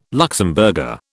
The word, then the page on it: Luxemburger: British English